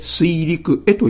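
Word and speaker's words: 水陸会: 水陸へ、水陸、そして会議の会と書いて水陸へと読みます。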